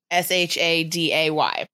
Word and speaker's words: Sade: First of all, I believe even though it's spelt Sade, I'm pretty sure it's pronounced Sade. – Sade, good call. – Or Sade. I'm not sure where the emphasis goes, but it's I wrote down pronounced Shaday.